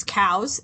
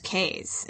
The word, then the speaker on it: Cowes: It's Cowes if you don't live there. And if you do live there, it's Cays.